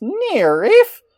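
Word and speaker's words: NIIRYF: Those are the letters for the podcast title, and it's pronounced NIRIF.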